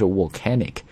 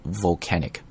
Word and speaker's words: volcanic: 那么中国同学在读这个单词的时候呢，经常把这个 V 的这个 V 的发音跟 W 的这个 W 的发音搞混，所以很多人会把它读成 volcano 或者是 volcanic。大家呢在跟我们的这个晨读进行朗读的时候，一定要自检啊，把自己的语音录下来看一看是不是真读成了 volcanic, 应该读的是 volcanic, V 这个音 volcanic ash, ash 表示的意思是灰尘。